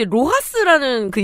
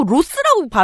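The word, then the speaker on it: LOHAS: 좀 이제 뭐 웰빙, 근데 로하스라는 그인증 있잖아요. 음. 예전에 무슨 발표를 듣는데 자꾸 그, 그 어떤 그 발표자가 자꾸 로스라고 발음한 거 로스.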